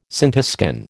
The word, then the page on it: scintiscan: American English